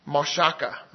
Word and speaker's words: mashach: It's spelled M-A-S-H-A-C-H, and it's pronounced mashaka. I'm sorry, mashaka.